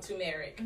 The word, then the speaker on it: turmeric: What about the correct pronunciation? – Turmeric.